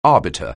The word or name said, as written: arbiter